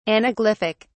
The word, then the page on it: anaglyphic: British English